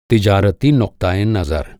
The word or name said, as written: नुक्ता-ए-नज़र